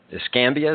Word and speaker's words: Escambia: agnostic pantheist or something like that has been trying to get to do the invocation at the Pensacola or the Escambia.